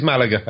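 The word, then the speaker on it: Málaga: It is Malaga, as you said. You started Malaga. Off by saying Malaga.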